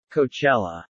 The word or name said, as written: Coachella